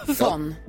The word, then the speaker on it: von: von. Man uttalar det med F, men det stavas med.